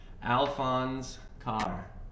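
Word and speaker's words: Alphonse Karr: all right, this is by, I had to look up how to pronounce this guy's name, Alphonse Carr. – Oh, Alphonse. – Yeah, Alphonse Carr.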